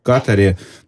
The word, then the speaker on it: Катаре: Ну вот так и не понял я все-таки, где ударение правильно ставить, в Катаре или в Катаре, ну, или в Катаре. – Только в этом году мы поняли, что на самом деле на второй слог.